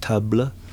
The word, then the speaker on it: tabla: It's in some circles, probably in the correct circles, it's pronounced tabla. Tabla, because they're little tubs.